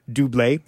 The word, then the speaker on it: Dublé: Dublé, it's spelled double, but it's pronounced Dublé, is not available on Rhapsody.